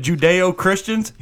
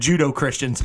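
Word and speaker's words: Judeo-Christian: Instead of Judeo-Christians, he said Judo-Christians 3 times.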